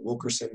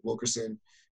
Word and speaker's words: Wilkerson: LinkedIn, you can connect with me on LinkedIn, Philip Wilkerson III, Wilkerson, W-I-L-K-E-R-S-O-N, and Philip is spelled with one L, the right way.